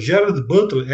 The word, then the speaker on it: Gerard Butler: O eu vi na pronúncia Gerard Butler, é isso, Luciano? O nome do nosso é Isso, exatamente.